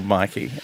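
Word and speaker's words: myki: The tram home with my Miki. – In Victoria, we have a card called Mikey, and it's not pronounced Miki. Thank you, Toby, for attempting that.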